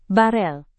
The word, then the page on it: barel: indonesian